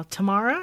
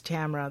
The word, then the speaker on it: Tamara: My mom was sadistic because everyone's all, Tamara? And I'm like, no, it's Tamara.